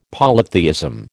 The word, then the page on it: polytheism: American English